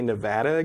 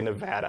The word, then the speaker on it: Nevada: People who live in Nevada say Nevada.